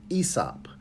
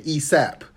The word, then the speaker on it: ESOP: So now, um, as of last year, uh, December, KKW is now an ESOP, and that's spelled E S O P. When I first found out about it, about it, I was calling it an ESAP Oh, I didn't because oh, okay.